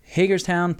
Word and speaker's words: Hagerstown: Tony, from a Maryland boy, you pronounced Hagerstown perfectly this time.